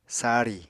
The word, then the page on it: sari: apatani